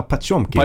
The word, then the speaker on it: Potemkin: Potemkin. – Ha. Du menar Pachomkin? – Pachomkin, ursäkta uttalet.